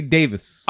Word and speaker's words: Davies: Is it Davis or Davies? – It's spelled Davies, but they always say Davis.